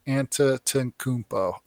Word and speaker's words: Antetokounmpo: So I was like, okay, Antetokounmpo.